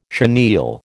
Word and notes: chenille: American English

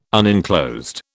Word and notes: unenclosed: British English

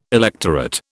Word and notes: electorate: British English

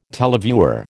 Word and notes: televiewer: American English

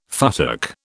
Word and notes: futtock: British English

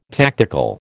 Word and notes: tactical: American English

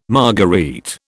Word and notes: marguerite: British English

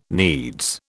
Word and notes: needs: British English

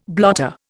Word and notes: blotter: Indian

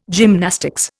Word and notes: gymnastics: Indian

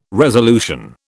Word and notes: resolution: British English

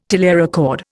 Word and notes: telerecord: Indian

